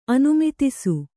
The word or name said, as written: ಅನುಮಿತಿಸು